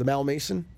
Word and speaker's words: Malmaison: And we stayed that night in Glasgow at the Malmaison. – Yep. – Am I saying that correctly? – Yep.